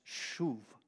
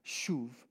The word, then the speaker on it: shuv: The root word on that is shuv. It means to repent. It means to turn around. The way you remember that in English from Hebrew, shuv, take the long U and make it short, and you'll have the picture.